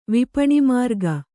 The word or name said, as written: ವಿಪಣಿಮಾರ್ಗ